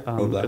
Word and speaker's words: Roblox: It's Roblox? Roblox? – Yeah, Roblox.